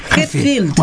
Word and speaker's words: Hetfield: Hetfield.